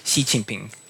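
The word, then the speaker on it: Xi Jinping (习近平): kas seda hääldatakse Xi, Xi või Xi, kellel on täielik kindlus sellest olemas? üks, kaks, kolm neli, äh, viis, kuus inimest, kelledest üks on Eesti üks juhtivaid äh, Hiina uurijaid. Klatime siis selle asja ära. – seda hääldatakse Xi Jinping.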